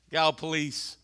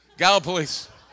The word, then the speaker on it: Gallipolis: Gallipolis rhymes with call the police. Gallipolis.